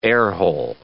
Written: airhole

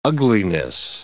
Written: ugliness

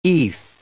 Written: eath

